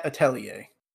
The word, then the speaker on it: atelier: Is that's how I assume you pronounce it? Because that, that's a that French is in word. fact how you how you pronounce atelier.